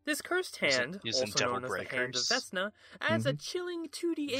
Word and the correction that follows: Vecna: He pronounces Vecna Vesna the entire video.